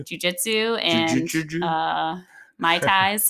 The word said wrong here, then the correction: Muay Thai: Muay Thai. Muay Thai.